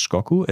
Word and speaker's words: Shikoku: Ja, es heißt Shikoku. Es wird zwar Shigoku Koku geschrieben, aber es wird Shikoku ausgesprochen.